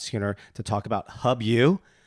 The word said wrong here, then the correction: Host U: Okay, see, I even said it wrong. Host U,